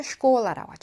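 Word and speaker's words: 슈콜라 (школа): 샤. 실제 발음은 슈. 그래서 학교라는 말을 슈콜라라고 하죠. 많이 들어보셨죠? 슈콜라.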